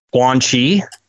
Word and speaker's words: Quan Chi: Guan Chi? – Uh-huh, yeah.